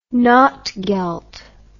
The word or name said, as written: notgeld